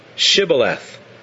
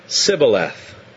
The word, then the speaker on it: Shibboleth: If he said no, then they would say to him, "Then say Shibboleth." And he would say, Sibboleth, for he could not pronounce it right.